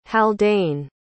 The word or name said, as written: haldane